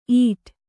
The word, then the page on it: ಈಟ್: īṭ